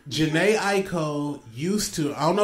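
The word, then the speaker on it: Jhené Aiko: Yes, Jenny Aiko.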